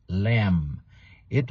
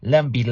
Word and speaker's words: lamb: But anyway, for those of you learning English as a second and foreign language, remember the pronunciation of the word lamb. It rhymes with the word I am, am. So don't pronounce the B. It's not lambi, lambi, lambi. No, it's lamb. Close your mouth at the M, M, lamb.